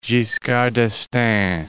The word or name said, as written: Giscard d'Estaing